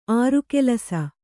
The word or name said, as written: ಆರುಕೆಲಸ